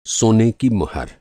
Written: मुहर

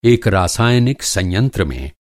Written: संयंत्र

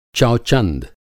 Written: चौचंद

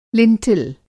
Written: लिंटल

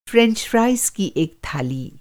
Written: फ़्रेंच फ़्राइज़

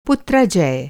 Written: पुत्राजय

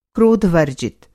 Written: क्रोध-वर्जित

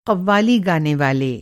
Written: क़व्वाली

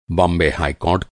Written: कोर्ट

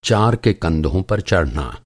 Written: चार

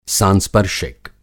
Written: सांस्पर्शिक

